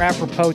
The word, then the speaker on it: apropos: All right, so one thing, another thing that's developing, and this is more apropos to the time on the calendar, McMahon, so you'll be happy about that. Trade deadline's coming up in 3 weeks. – Why don't you pronounce the S in apropos? – Pure Texan.